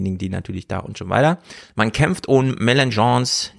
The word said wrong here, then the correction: Mélenchon: nee, wie heißt er richtig? Mel Melanchon, nee, wie? wie Melanchon. – Mélenchon, Mélenchon, genau. – Mélenchon, so spricht man ihn aus.